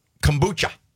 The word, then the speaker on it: kombucha: Kombucha. Did I pronounce it right? – Yeah.